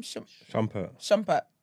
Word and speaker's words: Shumpert: Shumpert.